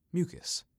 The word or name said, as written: Mucus